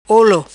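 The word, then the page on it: Olo: patua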